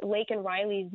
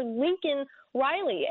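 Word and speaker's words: Laken Riley: And I mean, President Biden couldn't even say Lake and Riley's name last night. And when he finally did, he mispronounced it, calling her Lincoln Riley.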